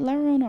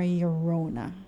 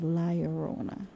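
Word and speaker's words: La Llorona: Is it Larona or Yorona? – It's. You know what? I'm not sure because the L. You're not supposed to say the two L's in Spanish is usually pronounced like a Y.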